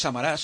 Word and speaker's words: Samaras: Bueno, Samaras le decimos aquí, pero pronunciado en griego, sí, esto es Samaras. – Samaras, según pone la transcripción en griega, es Samaras, está acentuada la última. – Sí, no Samaranch. ¿Eh? – Samaras. – Y no es Juan Antonio Samaras, sino...